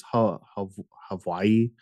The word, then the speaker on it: Hawaii: So I went to, I think it's Hawaii. There's actually like a V in the like correct pronunciation. Very strange.